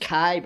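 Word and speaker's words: kai (καί): You see, that word in the Greek language is pronounced kai, very simple word, just like an, and yet it is a very definite connecting word.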